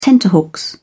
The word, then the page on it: tenterhooks: British English